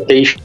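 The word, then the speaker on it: Teich: Teixe, não é isso? – É, Teixe. Nelson Teixe, é, a pronúncia é Teixe mesmo.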